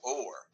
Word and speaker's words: Oher: Let me say his name right. Michael Orr. – Orr. That is a different way to say Orr.